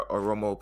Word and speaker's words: Oromo: What are the Oromo protests really all about? – Yeah. So the Oromo protest is all about,